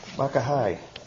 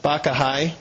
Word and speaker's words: Bacchae: Bacchae. – Bacchae.